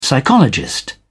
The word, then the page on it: psychologist: British English